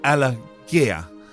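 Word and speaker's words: Alagiah: Uh, the BBC's George Allergy, uh, sorry, Allergia. Um, I can never say his name properly.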